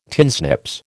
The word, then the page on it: tinsnips: American English